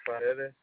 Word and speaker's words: Paredes: Robert Paredes. – Can you spell that last name for me, please? – P-A-R-E-D.